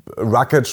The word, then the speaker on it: Rocket: Und dann, wenn wir jetzt mal durchzählen, wir haben den Sonra Rocket Bay, wir haben Rocket, spricht man übrigens auch ganz bestimmt nicht Englisch aus.